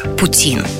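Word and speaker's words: poutine: Ну, еще ударение на французский манер – Путин.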